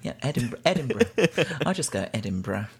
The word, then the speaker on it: Edinburgh: Well, that's how Americans say it, but I think that most British people say Edinburgh. – Edinburgh.